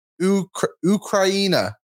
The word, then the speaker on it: Ukraina: Go, Ukraina. I believe it's how you say Ukrainian. Yeah, it is. – Okay. – I think Ukraina.